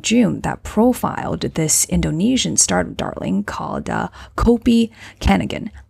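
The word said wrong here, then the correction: Kopi Kenangan: So in Indonesia, we say Kopi Kanangan, where Kanangan means something like a memory.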